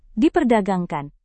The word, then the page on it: diperdagangkan: Indonesian